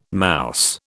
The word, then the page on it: mouse: British English